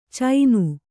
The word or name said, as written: ಚೈನು